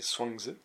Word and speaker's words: Chuang Tzu: On peut prendre ici l'exemple du penseur chinois de l'Antiquité, Chuang Tzu, ça dépend des façons de le présenter, mais enfin, je crois que la prononciation exacte c'est Chuang Tzu,